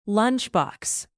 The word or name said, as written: lunchbox